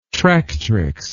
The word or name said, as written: tractrix